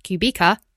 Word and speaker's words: Kubica: Add Kubica, pronounced Kubica.